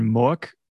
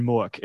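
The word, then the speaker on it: Mueck: The guy that plays Ludo, Ron Mook, is now this really famous, like hyper realist sculptor.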